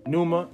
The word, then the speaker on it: Pneuma: So when you see Pneuma and the P is silent.